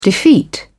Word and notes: defeat: British English

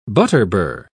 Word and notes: butterbur: British English

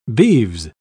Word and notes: beeves: British English

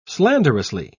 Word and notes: slanderously: British English

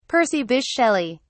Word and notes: percy bysshe shelley: British English

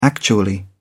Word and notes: actually: British English

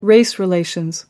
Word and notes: race relations: American English

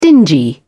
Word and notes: dingy: British English

